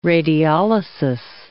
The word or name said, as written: radiolysis